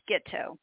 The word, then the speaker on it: Gitto: It's actually pronounced Gitto, not at all like Gitto.